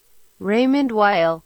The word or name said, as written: Raymond Weil